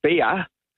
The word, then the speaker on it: bier: And that's actually pronounced B-I-E-R, and it's German. – Oh. – Like beer and beer.